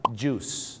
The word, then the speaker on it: juice: juice นะครับผม juice ไม่ได้ออกเสียงว่าจุ้ยนะฮะ juice นะครับผมท้องเสีย juice เลยนะครับผมน้ำผลไม้ไม่ใช่จุ้ยนะฮะต้องออกเสียงว่า juice